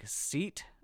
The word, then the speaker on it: SEAT: Spanish car maker Seat, whose name looks exactly like seat, S-E-A-T, but I assure you it's pronounced Seat, is just about ready to unveil their new 7-seat SUV.